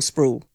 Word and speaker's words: Sproul: Do you pronounce it Sproul or Sproul? Sproul. It's like Sprout, isn't it, with an LL.